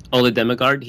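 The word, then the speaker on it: Ole Dammegard: O-L-E, that's the first name, and then Damegard is D-A-M-M-E-G-A-R-D. – D-E-M-M-A-R-D? – D-A-M-M-E-G-A-R-D.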